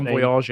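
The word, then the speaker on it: Monbiot: I'm sorry, I hate George Monboyard, how he pronounced his name, French name. Monboyard, yep.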